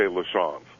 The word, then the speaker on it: LaChance: You know, in Missouri, they say LaChance, unless you go to southern Missouri, where it's a little bit more French than it's LaChance.